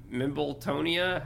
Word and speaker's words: Mimbletonia: And I did write down that Neville winds up, he gets a Mimbletonia.